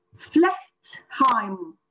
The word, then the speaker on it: Flechtheim: Ama fütürizm kavramı ve kelimesi yani bu işe bu adın verilmesi bir Alman profesör Flechtheim herhalde diye düşünüyorum. – Osip, Oldukça yakın bir Almanca telaffuz oldu.